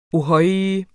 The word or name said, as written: ohøj